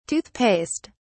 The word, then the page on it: tooth paste: British English